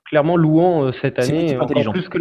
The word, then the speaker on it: Luan: Ah bah oui, mais c'est la prononciation brésilienne, c'est Luan.